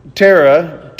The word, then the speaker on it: Terah: And Haran died before his father, Terah, and how do you want to say that? Somebody pronounce it for me. So it's Terah. Okay, Terah.